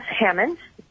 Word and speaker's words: Hamon: My name is Rebecca Heyman. Last name is spelled H-A-M-O-N.